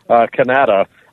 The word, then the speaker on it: Kanata: So is it spelled the same as Canada? – No, it's spelled with a K, Kanata.